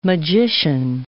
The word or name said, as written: magician